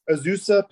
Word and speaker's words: Azusa: That school, Azuna, is that how you say it? – Azusa Pacific. Azusa.